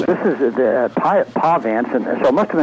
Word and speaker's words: Pahvants: And I hadn't really heard of this group, but it's spelled P-A-H-V-A-N-T-S, Pahvants.